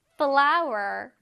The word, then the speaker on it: flour: 그다음에 flower, flower. 밀가루라는 뜻이에요. 그쵸? 쌤이 아까 말했듯이 꽃 flower랑 발음이 동일합니다. 하지만 스펠링이 다른 거꼭 기억하자, 얘들아.